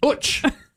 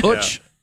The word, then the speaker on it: Uch: So it's like Butch without the B. – I get it. Uch.